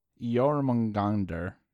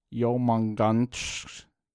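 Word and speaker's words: Jörmungandr: Jormungandr is how it's sometimes pronounced in English, but in Norse it's. Hold on. Yomanganch.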